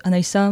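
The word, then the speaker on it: Anaysa: En vrai, mon prénom, il est chiant, hein, mais je l'adore, hein, mais c'est Anaïssa, ça se prononce Anaïssa, mais il y a Y et un seul S.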